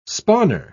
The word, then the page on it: spawner: British English